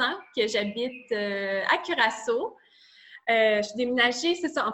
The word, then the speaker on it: Curaçao: Donc, euh, qui est en direct de, je vais sûre de bien le prononcer, Curaçao. Je l'ai... Curaçao. Curaçao. C'est parfait.